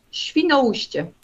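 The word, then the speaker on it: Świnoujście: Äh, wie klingt das auf Polnisch? Schwinaustje.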